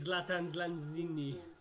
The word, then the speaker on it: Lanzini: This is Lanzini.